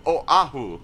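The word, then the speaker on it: Oahu (Oʻahu): They're pausing at the Okina, they're stopping. So, Oahu.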